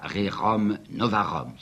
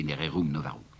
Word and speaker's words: Rerum Novarum: D'autre part, deuxième coup de tonnerre, celui-là est du 15 mai 91, l'année suivante, c'est la fameuse encyclique que je prononcerai comme on prononçait à ce moment-là, Rerum Novarum, si vous voulez, Rerum Novarum.